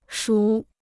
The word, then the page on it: 属: shǔ